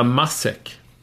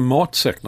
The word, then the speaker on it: matsäck: Heter det matsäck eller massäck? – Det heter matsäck naturligtvis.